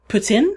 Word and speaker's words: poutine: One, poutine, or actually, I think the pronunciation that's closer to it, it's not exact, because I know this is not exactly the right pronunciation, but the closer pronunciation is poutine. I think there's a little slight S sound in the middle there somewhere.